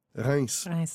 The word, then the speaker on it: Reims: How do you pronounce that, Jean Benoit? Rinse. Rinse. Yes, like a rinse.